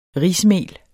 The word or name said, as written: rismel